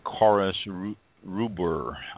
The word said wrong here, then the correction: Quercus robur: I believe is pronounced, Quercus Rubers, Quercus Robur.